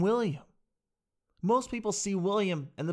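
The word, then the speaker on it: will.i.am: And this is a gentleman, William, and it's actually pronounced Will I Am.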